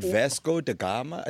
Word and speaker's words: Vasco da Gama: You said Vasco da Gama? It sounded like one word. – That's how you say it, bro.